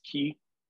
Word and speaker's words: Quay: We stayed right in the middle of it, right on, spelled like you would pronounce it Quay Street, but mm-hmm. it's pronounced Key.